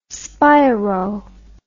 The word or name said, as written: spiro